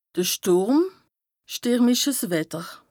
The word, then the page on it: de Stùrm, stìrmiches Wetter: Bas Rhin; Strasbourg